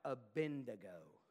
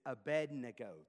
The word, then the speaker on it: Abednego: And before we go on, real quick, it's not Abednego, it's Abednego. The D comes before the N.